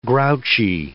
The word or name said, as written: grouchy